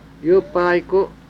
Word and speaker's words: Yupaiko: How do you say near? Yupa? – Yupaiko. Yupaiko. Yupaiko.